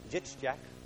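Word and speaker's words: Jitschak (יצחק): It's pronounced Jitschak, which doesn't sound like Isaac at all to us.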